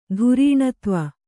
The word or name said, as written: ಧಾರಿಣೀಧವ